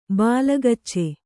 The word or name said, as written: ಬಾಲಗಚ್ಚೆ